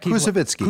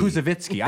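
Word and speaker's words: Koussevitzky: Tanglewood on Parade dates back to the 1940s when the BSO music director and Tanglewood founder, Sergei Kusik, how do I say that? Kuzovitsky. Kuzovitsky.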